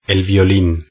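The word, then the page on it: el violin: Spanish (Spain)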